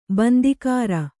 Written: ಬಂದಿಕಾರ

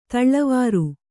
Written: ಧಾಳಿವರಿ

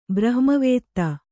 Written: ಬ್ರಹ್ಮವಿದ

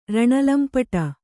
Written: ರಣಲಂಪಟ

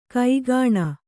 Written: ಕೂಗಣಿ

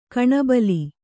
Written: ಕಣ್ಬೀಲಿ

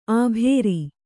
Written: ಆಭೇರಿ

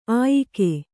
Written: ಆಯುಗ

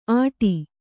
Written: ಅಟಿ

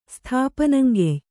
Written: ಸ್ಥಾಪನಂಗೆಯ್

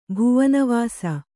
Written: ಭುವನವಾಸ

